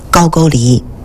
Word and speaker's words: 高句丽: 他去打辽东大地上的一个强国高句丽啊，这个不要混为是高丽啊，也不要读成高句丽啊，它的正音是高句丽。